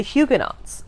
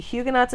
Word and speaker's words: Huguenots: For instance, the Huguenots of France. So Huguenots is spelled H-U-G-U-E-N-O-T-S, but these are Calvin's French followers.